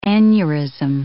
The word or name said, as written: aneurysm